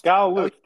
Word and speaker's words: Gal Luft: He's an Israeli professor in – is that how you say his name? Gal Luft? Luft? – Gal Luft. Yeah, he's an Israeli-American.